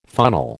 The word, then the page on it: funnel: American English